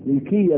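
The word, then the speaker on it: للكيا: الكتاب الذي معنا هو أحكام القرآن ملكية هراتي، كما أنطقها كذا، أحكام القرآن مين؟ ملكية. ولازم تعرف تنطق اسم الكتاب وصاحب الكتاب، إذا أحكام القرآن مين؟ ملكية بكسر الكاف، ملكية هراتي.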